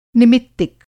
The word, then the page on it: निमित्तिक: Hindi